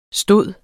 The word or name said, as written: stod